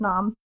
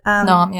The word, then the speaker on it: Noom: And I'm pretty sure that's how you pronounce it, NOM. But NOM, yeah. That's how I pronounce it as well. – It's like you're eating something like nom, or something.